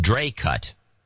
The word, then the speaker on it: Dracut: By the way, my town is pronounced Dracut.